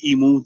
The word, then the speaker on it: imu: You know, it's like I said, we call it an emu, they call it an umu. – Yeah. – U-M-U, whereas we pronounce it I-M-U, yeah? Emu.